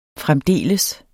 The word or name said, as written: fremdeles